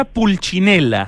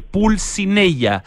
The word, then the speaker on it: Pulcinella: pizzería Pulchinela, se escribe pizzería con dos Z, y Pulchinela es con P, con C, con N y con dos L, Pulcinella, pizzería pulcinella.cl, y para poder pedir el delivery de esta pizza napolitana de verdad, de a de veras.